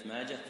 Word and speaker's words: ماجه: يا صاحي نطق ماجة بالها بلا لجاجة، يا صاحي نطق ماجة بالها بلا لجاجة، يعني كلمة ماجة تنطق بالها ولا يقال ماجة. هذا الحديث كتبته يا صاحي، صاحي يعني ايش؟ هو صاحي ان شاء الله، انك يا صاحي يعني يا صاحب. يا صاحي انطق ماجه بالهاء بلا لجاجه، بالهاء بدون همزه، يا صاحي انطق ماجه بالهاء بلا لجاجه.